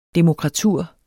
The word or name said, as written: DEMOKRATUR